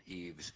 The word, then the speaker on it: EEVS: One of which was the Electronic Employment Verification System, EEVS, or as they like to pronounce it, EVES, which is being developed by Homeland Security for the Social Security Administration.